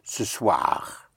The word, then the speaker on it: ce soir: In French, you say this evening, which is ce soir. Ce soir.